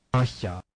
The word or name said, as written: c cedila